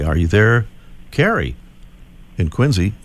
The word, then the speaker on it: Quincy: I know it's spelled Quincy, but we pronounce it Quincy around here.